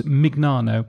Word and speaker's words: Mignano: I'm calling him Mignano. He is, of course, Mignano, because he pronounces it the American way.